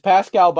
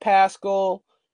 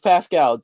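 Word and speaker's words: Paschall: And for reference, the five guys are Eric Pascal. It's Pascal, by the way, not Pascal. It's Pascal.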